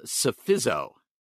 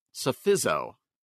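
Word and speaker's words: Psephizo: So I'm going silent P. I'm going that this is called Sophizo. Sophizo.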